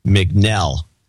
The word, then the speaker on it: McNell: No, David McNeil in Winfield, Illinois, 20150. Last name rhymes with Bell. McNell. David McNell. It looks like an I, sorry.